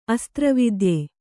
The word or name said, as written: ಅಸ್ತ್ರವಿದ್ಯೆ